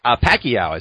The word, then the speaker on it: Pacquiao: Pacquiao is how I've always heard.